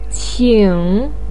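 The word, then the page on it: qing3: tone 3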